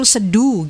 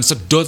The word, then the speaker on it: Sedo: Salah. Katanya Sedo. Yang betul Sedu gitu. – Oh Sedu. – Sedu. Daerah Sedu. – Bukan Sedo tapi Sedu.